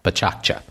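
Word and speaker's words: PechaKucha: That's spelled P-E-C-H-A, new word, K-U-C-H-A, pronounced in the Japanese style, Pechakcha, which is Japanese, I've been told, for chit-chat.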